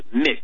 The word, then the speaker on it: Mx: My prefix is MX, period, pronounced mix.